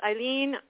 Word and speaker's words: Eileen: My name is Eileen.